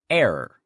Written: error

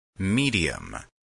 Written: medium

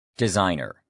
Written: designer

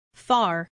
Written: far

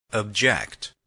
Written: object

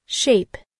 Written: shape

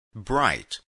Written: bright

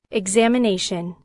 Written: examination

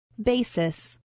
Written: basis

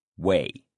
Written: weigh

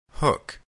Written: hook

